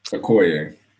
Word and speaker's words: Okoye: How do you say her name? Oyoke for Okoye.